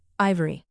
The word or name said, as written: ivory